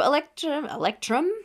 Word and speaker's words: Electrum: So Electrum, there we go.